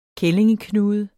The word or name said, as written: kællingeknude